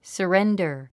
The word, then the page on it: surrender: səréndə r; サレンダァ